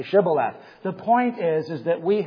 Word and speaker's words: Shibboleth: If he said no, then they would say to him, "Then say Shibboleth." It's kind of like a fountain of springs. And he would say Sibboleth, for he could not pronounce it right.